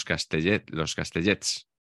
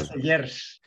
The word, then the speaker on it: Castellers: Decimos los Castellets normalmente, que ¿sí? Lo decimos mal. – Castellers.